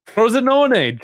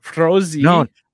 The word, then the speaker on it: Frosinone: So they were up 2-0, and it's a little bit of a joke that Dave and I have with, hopefully I pronounce this right, Frosinone. – Frosinone, Jesus, it's not hard. Frosinone. – Why do I keep forgetting the N? I always forget the N in the middle, but we call them Juventus B because they have three of our starlets.